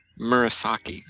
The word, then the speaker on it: Mirasaki: This planet is in the middle of the Mirasky 312, a large quasar-like formation. Am I saying that right? – Mirasaki. – It's not Mirasky playing left forward for the Philadelphia Flyers.